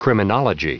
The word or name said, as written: criminology